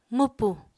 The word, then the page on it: mwpu: Apatani